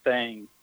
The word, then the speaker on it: thang: Uh, one thing that a lot of people talk about in Alabama, they call it the Alabama white thing, and it's thing T H A N G. You have to spell it. So not thing,